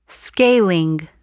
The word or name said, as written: scaling